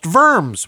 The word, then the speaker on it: Worms: In French, worms is pronounced verms with a long O, and yes, the S is pronounced.